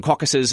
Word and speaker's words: Caucasus: As we mentioned earlier, the plural of a political caucus is caucuses, and that sounds just like the name of the mountain range. But the caucuses, as it refers to the mountain range, is spelled C-A-U-C-A-S-U-S.